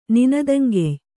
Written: ನಿನದಂಗೆಯ್

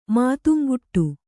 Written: ಮಾತುಂಗುಟ್ಟು

